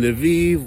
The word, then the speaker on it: Lviv: So as you guys know, every night here in Lviv, which rhymes with Tel Aviv,